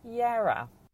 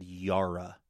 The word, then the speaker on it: Yarra: Sadly, we pronounce this as Yarra. – Yarra. All right. I think I might have said Yarra ranges.